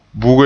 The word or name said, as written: buge